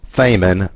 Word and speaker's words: Feynman: I think, you know, Richard Feynman's the main guy, and his name is spelled F-E-Y-N-M-A-N. So if you're reading it in text with a TTS voice and it's pronounced Feynman, that's the reason, but his name's pronounced Feynman.